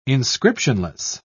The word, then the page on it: inscriptionless: British English